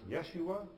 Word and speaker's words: Yeshua: It's a name that in Hebrew was pronounced Yeshua or Yeshua, but in English would be pronounced Jesus.